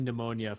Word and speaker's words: pneumonia: So I'm trying to pull it up, but spelling pneumonia for me is very difficult. – P-N-E. – And I got you. I was like, is it P-H? P-N? – There's a silent P.